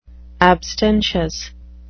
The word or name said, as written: abstentious